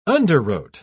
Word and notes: underwrote: British English

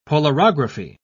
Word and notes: polarography: British English

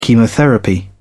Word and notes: chemotherapy: British English

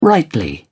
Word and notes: rightly: British English

